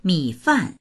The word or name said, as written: mi3fan4